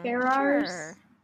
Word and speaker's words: Ferrars: Is that how I'm pronouncing it? Ferrars? – Sure. Edward Ferrero Rocher.